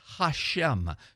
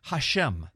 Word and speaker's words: Hashem: And in Hebrew, that's pronounced Hashem, two words, ha meaning the, and then shem meaning name. Hashem, the name.